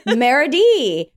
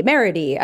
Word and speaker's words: Merity: Next up, Merity. Rhymes with charity. Merity.